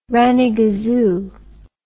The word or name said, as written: rannygazoo